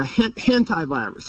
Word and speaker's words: hantavirus: And how do you pronounce it again? The WHO virus? – The hantavirus.